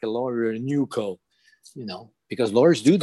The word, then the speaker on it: NUCO: and they'll call it N-E-W-C-O at the beginning. But we heard it like N-U-C-O, so it became the name. NUCO International wanted to keep it fresh, keep it open, to keep it new. So N-U-C-O, pronounced NUCO International in one word, .com.